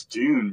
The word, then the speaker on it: Dune: It's Dune, bro.